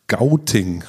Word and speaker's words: Gauting: ähm, Bayern, nach Gauting.